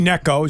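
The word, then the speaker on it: Gnecco: No, it's G-N-E-C-C-O. – Oh, okay. – The G and the second C are silent. – Kind of like gnocchi. – Kind of, yeah, very much like that.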